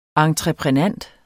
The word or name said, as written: entreprenant